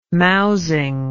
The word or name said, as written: mousing